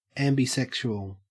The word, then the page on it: ambisexual: Australian English